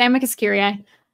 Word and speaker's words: amicus curiae: It's amicus curiae. Is it Latin? – Mm-hmm.